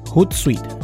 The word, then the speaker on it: Hootsuite: herramienta que se llama Suit. o no ¿cómo se dice? Shot, Hootsuite, Hootsuite.